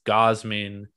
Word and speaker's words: Gausman: I've been pronouncing your name wrong for like 2 years. It's Gausman.